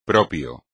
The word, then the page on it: propio: espagnol